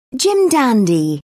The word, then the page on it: jim dandy: British English